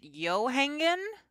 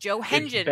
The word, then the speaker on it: Johengen: Is it Johangen? – It's actually Johengen. – Johengen.